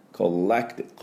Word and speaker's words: collective: Right は R ですね、桑田さんの Ra で、 Collective L ね、 Collective Colle のところにアクセントがあって、 Co のところは半母音、また弱母音です。あ、こっていうだけ。